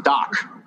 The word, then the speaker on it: Dak: It's not far, but it is, it's called Doc and D-A-K. – Yeah, spelled like Dak Prescott, but it's called Doc.